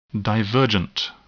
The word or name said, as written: divergent